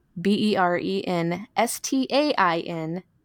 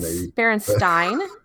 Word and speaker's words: Berenstain: if you go and look at all the books, they all say the B E R E N S T A I N Bears, the Berenstain Bears. Why would we all pronounce the word that way? – I've never heard of, of Frankenstein.